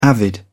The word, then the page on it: avid: British English